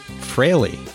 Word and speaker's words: Frehley: I would like to say that I have seen an interview of Ace on YouTube, and he pronounced it Frehley, as in Frehley's Comet.